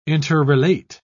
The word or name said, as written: interrelate